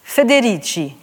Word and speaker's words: Federici: « Caliban et la sorcière » Silvia, avec des « i », Federici, comme ça se prononce.